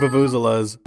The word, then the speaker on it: vuvuzela: Pretty sure it's pronounced vuvuzela. The accent is on Z.